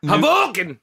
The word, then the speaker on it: Hoboken: Is that how you pronounce that? – No, it's Hoboken!